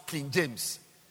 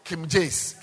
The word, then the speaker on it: King James: Still, even the name of the Bible you are using, the Bible, you cannot even pronounce King James. You are saying Kim Jace, Kim Jace, Kim Jace. It's not right. Kim Jace.